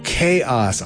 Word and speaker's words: K-os: After that, Crab Bucket doing, I think it's either Koss or K-OS. That's how it's spelled. We'll call it Koss. Chaos? Oh, Chaos. I get it.